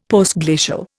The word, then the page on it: Postglacial: Indian